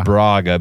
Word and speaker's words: Braga: I say Braga because most of you Americanos out there can't roll your R's. Braga. Get some culture.